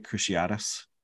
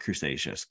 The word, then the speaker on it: Cruciatus: So we know, given his history with did I say that right? Cruciatus? – I think so. – Yeah. Not Crustaceous, because that's almost like crustacean.